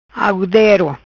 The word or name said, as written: Algudero